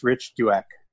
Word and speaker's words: Douek: Well, I mean, my last name has three vowels in a row, so it's a challenge. But it's Rich Douek.